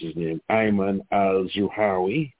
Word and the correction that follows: al-Zawahiri: You know, he gave Medicare to the poor. – Yeah, Al-Zawahiri. Al-Zawahiri.